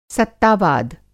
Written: सत्ता-वाद

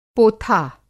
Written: पोथा

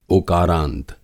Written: ओकारांत

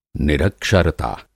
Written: निरक्षरता

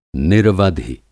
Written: निरवधि

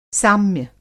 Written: साम्य

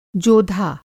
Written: जोधा

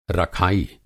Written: रखाई

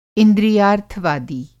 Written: इंद्रियार्थवादी

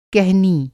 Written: कहनी

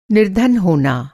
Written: निर्धन

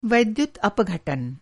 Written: वैद्युत् अपघटन

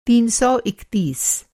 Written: तीन सौ इकतीस

